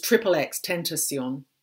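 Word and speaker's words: XXXTentacion: I can't say his name, but it's Triple X Tentacion, Triple X.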